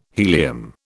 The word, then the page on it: helium: British English